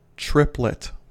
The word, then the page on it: triplet: American English